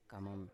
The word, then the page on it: kamam: Biak